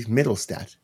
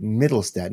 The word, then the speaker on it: Mittelstadt: All right, our next guy on the list here is from the Buffalo Sabres, Casey Mittelstadt. I always pronounced it Mittelstadt, not Mittelstadt. Oops.